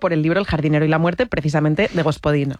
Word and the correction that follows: Gospodinov: A ver, Georgi Gospodinov, ¿sí? – Zdravka Eftimova y Vera Mutavcheva.